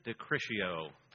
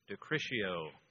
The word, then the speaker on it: DiCriscio: It looks like DiCrisco. And 90% of the people who spell it or say it that way. The D in DiCriscio in Italian means of or from.